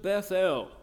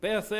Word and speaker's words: Bethel: And he removed from thence unto a mountain on the east of Bethel, and pitched his tent, having Bethel, or as we often say, Bethel. Sometimes we should emphasize the syllables, really.